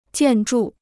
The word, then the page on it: 建筑: jiàn zhù